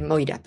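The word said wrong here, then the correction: Morya: perdón. De Moria, lo he dicho bien.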